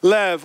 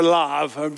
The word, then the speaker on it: Lev: Lev or lav, depending on how you pronounce the vowel, okay?